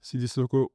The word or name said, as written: Sidy Cissoko